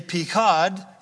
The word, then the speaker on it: Pekod: And in Hebrew you say Pekod, and it means to punish.